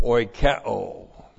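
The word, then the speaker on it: oikeo (οἰκέω): And then we have the verb oikeo. O-I-K-E long O. Oikeo.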